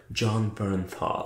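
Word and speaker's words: Jon Bernthal: Az egyik, az John Brentel, aki tehát Ja, Berntál. – Igen, igen, bocsánat, igen. – nem tudom kiejteni a nevét, uh, majd egyszer megtanulom. Szóval a lényeg az, hogy... Nem színász, az úgy van, John Bernthal. Oké.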